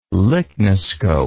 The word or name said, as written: lychnoscope